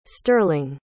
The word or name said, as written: stirling